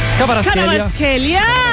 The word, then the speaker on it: Kvaratskhelia: Gavicha Kvaratskhelia, ¿cómo se dice eso? ¿A usted le gusta? – Kvaratskhelia.